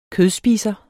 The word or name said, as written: kødspiser